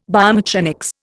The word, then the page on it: biomechanics: Indian